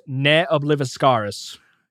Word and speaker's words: Ne Obliviscaris: So this is an Australian, and I'm going off of Metallum, of course, an Australian extreme progressive metal band, and apparently it is pronounced Ne Obliviscaris. – Yep. – Cool. I will probably end up saying I've been pronouncing it right. Nea Obliviscaris.